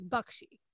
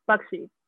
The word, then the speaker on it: Baxi: Because I bring this up because like, our last name, our maiden name is B-A-X-I. And the way to pronounce it properly is Bakshi. The X is a ksh, like a ksh sound. – Bakshi.